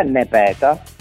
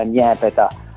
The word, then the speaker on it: Nepeta: Sì, un errore comune, diciamo, la pronuncia dipende un po' dalle province siciliane in cui ci si trova. In realtà è Nepeta, in siciliano dalle nostre parti detta Niepeta, ed ecco anche perché l'accento sulla prima a volte.